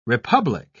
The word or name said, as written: republic